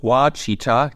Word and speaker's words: Ouachita: Es gibt unter anderem da einen 360 Kilometer langen Wanderweg, den Ouachita, diesmal habe ich es richtig ausgesprochen, National Recreation Trail.